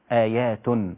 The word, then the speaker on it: آيات: طيب تاء ضم هتبقى تتنطق إزاي؟ آيات آيات بينات مقام إبراهيم.